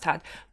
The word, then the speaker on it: Thad: Basically, um, the author Tad it's spelled Thad, but I'm assuming yeah. – it's, it's, pronounced Tad Beaumont, um, who writes under an alias George yeah.